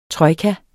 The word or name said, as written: TROJKAER